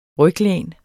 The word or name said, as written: ryglæn